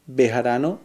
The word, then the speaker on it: Bejarano: Mi nombre es Jairo Bejarano Carrillo. – 2. ¿Cómo se escribe tu nombre? – Jairo se escribe J-A-I-R-O. – 3. ¿Cómo se escriben tus apellidos? – Bejarano se escribe con B de burro, B-E-J-A-R-A-N-O. Y Carrillo, C-A-R-R-I-L-L-O.